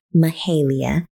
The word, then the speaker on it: Mahalia: Jacob Collier has an amazing year for music, and he released a single last week entitled "All I Need" featuring Mahala or Mahalia. Do not know how to pronounce it, and I think I did it right the second time. Mahalia.